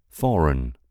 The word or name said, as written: foreign